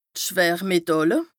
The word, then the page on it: d' Schwërmetàlle: Bas Rhin